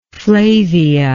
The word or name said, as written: flavia